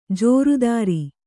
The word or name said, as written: ಜೋರುದಾರಿ